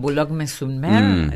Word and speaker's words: Boulogne-sur-Mer: En Boulogne-sur-Mer, uh-huh.